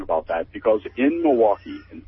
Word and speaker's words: Milwaukee: Milwaukee. – Yeah, no, except if you're from Milwaukee, it's called Milwaukee. It's two syllables.